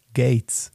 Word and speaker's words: Gaetz: Und der letzte, den ich euch heute vorstellen werde, heißt Matt Gaetz. Das spricht man wie Bill Gates aus, nur dass man den mit A-E-T-Z schreibt.